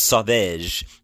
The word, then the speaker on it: Sauvage: or in American term, Savage, but it's pronounced Sauvage Dior line.